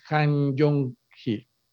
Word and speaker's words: Han Jong-hee: es Han Jong-hee. Muy bien. A ver si lo pronuncié bien, Tocayo. Bien pronunciado, Tocayo.